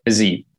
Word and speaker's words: Fiziev: I think the fight of the night is going to be Brad Riddell versus Rafael Fiziev. That's how you say it. Fiziev.